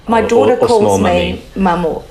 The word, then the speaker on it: maman: Because it's spelled M-A-M-A-N. I would have said maman. – Oh, yeah. Well, it's French. – Yeah, so, but it's... – Maman. – Maman.